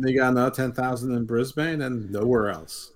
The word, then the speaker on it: Brisbane: Brisbane. – Oh, you said it properly for once. Every American says Brisbane.